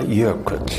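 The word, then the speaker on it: Eyjafjallajökull: А в исландском посольстве уверены, есть лишь один вариант произношения, и звучит он так. Эйяфятла Эйяфьятлайокудль.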